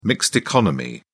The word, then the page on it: mixed economy: British English